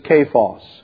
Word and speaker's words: Cephas: And the correct pronunciation of Cephas is Cephas. It is pronounced with a hard K,